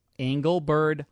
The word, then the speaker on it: Engelbert: Engelbert Humperdinck. – No, but you didn't have to say it correctly. It's Engelbert. – Oh, Engelbert. – Not Engelbert.